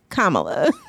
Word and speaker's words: Kamala: Kamala. – Just like Kamala. Yep. – Kamala, there we go. Because now I see it. COMMA. I see it the way that it's phonetically should be written.